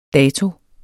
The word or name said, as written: dato